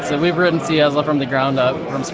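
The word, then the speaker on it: CyaSSL: We're at Saya SSL, is that right? – We pronounce it C-Yazzle. C-Yazzle. – Okay, can you spell that? That's Charlie Yankee Alpha Sierra Sierra Lima.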